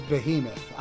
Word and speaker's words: Behemoth: Is it Bohemoth or Behemoth? Am I pronouncing this right? – I think it's Behemoth. I think it's that. – All right. – Mean, like, you know, it's an English word.